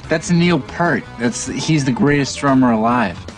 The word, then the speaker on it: Peart: And of course, Nick, Jason Segel's character, responds that, "Well, that's Neil Peart." – Right. – That's the way I always used to pronounce his name too, but it's actually Peart.